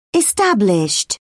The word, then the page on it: established: British English